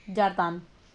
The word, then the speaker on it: jardin: Ah, ¿saben que en francés jardín se escribe jardín como en español? Pero se pronuncia jardin. – Jardin.